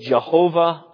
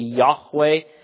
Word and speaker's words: Yahweh: Some people would say it Jehovah. I believe it's pronounced more appropriately Yahweh.